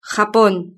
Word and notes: japon: Spain Spanish

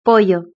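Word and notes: pollo: European Spanish

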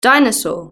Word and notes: dinosaur: British English

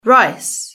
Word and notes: rice: British English

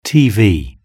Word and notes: tv: British English

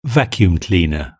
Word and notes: vacuum cleaner: British English

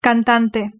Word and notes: cantante: Spain Spanish

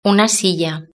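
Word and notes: una silla: European Spanish

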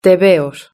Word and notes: tebeos: Spain Spanish

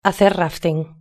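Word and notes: hacer rafting: Spanish (Spain)

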